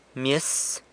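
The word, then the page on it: myes: San Lucas Quiaviní Zapotec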